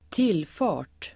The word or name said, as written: tillfart